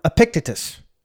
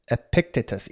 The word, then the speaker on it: Epictetus: And a quote from Epictetus, he's a Greek philosopher, and I may have been saying his name wrong. He's not here to defend himself, so Epictetus, E-P-I-C-T-E-T-U-S, Epictetus, said this.